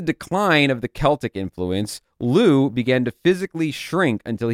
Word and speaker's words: Lugh: Their theory, well, not their theory, but what they put forward is millennia ago, Lu, L-U-G-H, but pronounced Lu, was the Celtic god of sun and light.